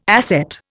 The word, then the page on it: asset: Indian